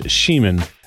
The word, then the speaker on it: Scheman: My last name is Scheman. S-C-H-E-M-A-N.